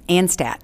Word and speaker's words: Anstadt: Can we start with you correctly pronouncing your last name for me? – Mm-hmm. Anstadt.